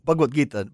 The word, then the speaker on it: Bhagavad Gita: Bhagavad Gita, but I have not been reading.